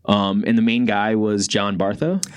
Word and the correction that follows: Bernthal: Did I say that right? Bartar? – John Bernthal.